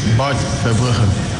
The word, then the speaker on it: Bart Verbruggen: Bart Verbruggen.